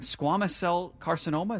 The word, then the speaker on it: squamous cell carcinoma: Art, you have, I'm not going to say it wrong, squamous cell carcinoma.